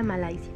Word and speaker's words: Malaysia: Malasia, en malayo se pronuncia Malaysia.